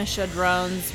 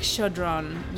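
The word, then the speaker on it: Chödrön: And then it's like Chodron, not Chodron.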